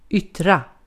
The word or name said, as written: yttra